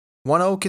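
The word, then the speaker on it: ONEOK: That is the correct pronunciation, by the way. I had to look it up. It's because a company that acquired the name rights pronounces its own name that way. That energy company is indeed named One Oak. That's O-N-E-O-K. One Oak, it's pronounced, and it's actually been headquartered in downtown Tulsa for over 7 decades and employs about 1,300 area residents.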